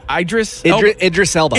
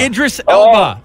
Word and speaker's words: Idris Elba: Idris Elba. – Idris Elba. Oh, also has the coronavirus, by the way. – Yeah. Does he really? – Yeah. Oh, no. Idris Elba.